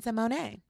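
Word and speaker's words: Raven-Symoné: It's Raven Simone. – Because she corrected us. – Absolutely. – In the most beautiful way and gave us the correct pronunciation of her name. – Yes, and it's Simone.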